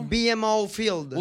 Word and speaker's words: BMO Field: BMO Field.